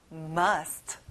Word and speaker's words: must: 자, must가 아니라 a 소리가 나요. must.